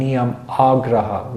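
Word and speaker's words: niyamāgraha: He says Niyam Agraha and Niyam Agraha with a long A.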